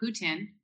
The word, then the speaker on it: poutine: Speaking of food, we talked years ago, 4 or 5 years ago, about poutine, which is a Canadian. I don't think I ever say it right, even though I'm Canadian, right? Well, how do you say it? – Poutine. – Poutine.